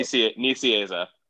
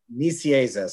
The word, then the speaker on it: Nicieza: Writer Fabian Nicieza. I'm not sure if I'm pronouncing Nicieza. – Nicieza, sorry about that.